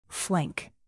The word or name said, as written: flank